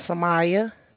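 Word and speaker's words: Sumaya: My name is Samaya.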